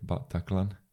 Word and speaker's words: Bataclan: Ja, het zijn drie lettergrepen, Bataclan.